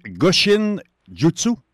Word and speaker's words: Goshin Jutsu: Donc, c'est Goshin Jutsu? – Oui, oui, en plein ça. – Ah, ben je prononce bien. C'est pas pire. Je m'améliore. – Oui, c'est très bon.